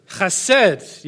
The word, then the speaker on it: chesed: Can anyone remember how to say the Hebrew word of loyal love? I want to hear the chesed. Yes, great. Thank you, Kevin. I heard that. It was brilliant.